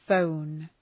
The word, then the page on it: phone: fəʋn